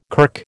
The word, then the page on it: kirk: American English